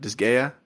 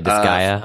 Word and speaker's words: Disgaea: Disgaea? – Yeah, Disgaea, I think.